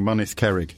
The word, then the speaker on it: Mynydd Cerrig: We'll begin with me almost certainly mispronouncing Mynydd Cerrig. – No, that's spot on. – Get actually. – In. – That is. You've pronounced that better than a lot of Welsh people would do, yeah.